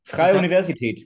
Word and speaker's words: Freie Universität: He's a doctoral fellow at the Graduate School of Global Intellectual History at the Free Universitat. – Freie Universität, but yeah, not bad.